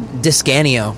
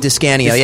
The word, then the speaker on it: DiScanio: How do you say your last name? – DiScanio. – DiScanio? – DiScanio, yeah. It's Italian.